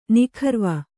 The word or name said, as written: ನಿಖರ್ವ